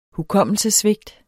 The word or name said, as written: Hukommelsessvigt